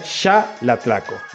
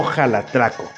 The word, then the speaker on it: Xalatlaco: Xalatlaco es un aguatlismo y se pronuncia xalatlaco, no jalatlaco. ¿Ok? No es como de dar jaladas, no, es xalatlaco. Y quiero que lo tomen en cuenta y se los voy a acentuar de esta manera.